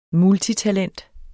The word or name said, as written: multitalent